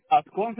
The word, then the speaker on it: Ascón: Carías Ascón. – Carías, ¿cómo se escribe el otro apellido? – Ascón se escribe A-S-C-O-N.